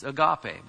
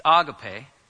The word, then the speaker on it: agape: In the Bible, you have this word agape, which many people mispronounce as agape, but that's fine. It's agape. The accent's on the end.